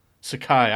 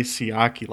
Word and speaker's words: Sakai: Sakai. I always want to say Siaki, like that wrestler.